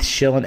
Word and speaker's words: Shillan: shillan mma my last name is spelled s-h-i-l-l-a-n